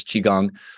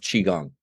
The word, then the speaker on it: Qigong: And what people don't realize is that Tai Chi is a form of Qigong. And Qi, it's spelled Q-I, means life force energy, or just energy. And Gong means to work with.